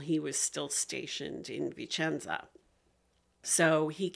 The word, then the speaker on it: Vicenza: So his Vicenza.